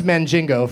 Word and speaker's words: Mandingo: It's pronounced Mandingo, first of all.